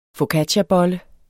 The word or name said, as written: focacciabolle